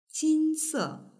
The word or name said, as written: jinse